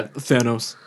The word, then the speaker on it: Thanos: Pronounced Thanos? – Yeah, Thanos.